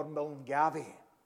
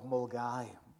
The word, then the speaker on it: Milngavie: And one of the joys for me was, John, we're all praying for Milngavie. And there's just no simple way to tell folks that that's not how you pronounce the joys of Mulgai.